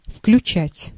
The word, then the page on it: включать: /fklʲʉˈt͡ɕætʲ/; vkljutšat